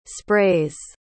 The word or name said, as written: sprays